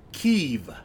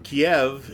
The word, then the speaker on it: Kyiv: That's the capital city of Kyiv. – Right. – Which is the Ukrainian pronunciation. – Right. And actually... – And Kiev is the Russian pronunciation.